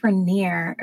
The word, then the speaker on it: Raniere: So Keith Raniere, that's how you pronounce it, he founded NXIVM along with Nancy Salzman, and they had names that they would refer to themselves as.